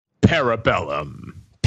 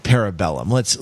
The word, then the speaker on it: Parabellum: Parabellum. – Parabellum. Yeah. Now, parabolas. – Literally, parabellum, right? It should be two words. And it should be pronounced like Latin, which is what it is.